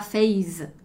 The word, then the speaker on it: Facebook: Os italianos também vão dizer com uma pronúncia italianizada e geralmente eles vão falar Facebook, Face. Então até o Face eles vão muitas vezes falar Face com um som de Z ali, ok?